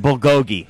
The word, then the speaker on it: bulgogi: No, you reveal your OU boxers to get a discount on your Bogolji. – Oh, okay, yeah. No, we didn't do any Bogolji. I don't know what that is. – It's a Korean delicacy. – That's actually pronounced Bulgogi.